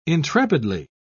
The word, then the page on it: intrepidly: British English